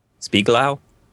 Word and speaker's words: Spiegelau: S-P-I-E-G-E-L-A-U. Spieglau? I'm assuming I'm pronouncing that somewhat correctly. – That sounds right.